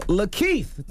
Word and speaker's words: LaKeith: Goes by the name of Lakeith. Did I say that right? – Yeah, right. That's right.